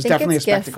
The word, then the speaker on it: GIF: That should definitely be a GIF or JIF, depending on how you pronounce it. So the Brett Kavanaugh, give us your thoughts on, well, really all of it. And I will call it a spectacle. – It's GIF, by the way, since it's graphic.